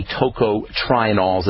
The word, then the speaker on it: tocotrienols: And by the way, tocotrienols is spelled T-O-C-O-T-R-I-E-N-O-L-S, tocotrienols.